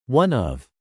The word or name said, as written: one of